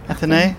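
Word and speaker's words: Athénée: The Plaza Athene. Is that how you pronounce A- it? Athene. – Athenay.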